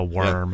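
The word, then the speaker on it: wyrm: We love a worm. Yeah. – I've definitely been, whenever I see that word, just call it a worm. So. – Weird. – That's how it's pronounced.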